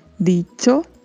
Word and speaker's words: dicho: Lembrando que se fala com o som de uma palavra que estamos habituados a falar, que é tchau. Então pronunciamos dicho, hecho, trecho.